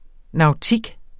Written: nautik

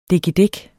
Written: dikkedik

